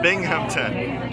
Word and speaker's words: Binghamton: Binghamton.